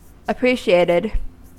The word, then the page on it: appreciated: American English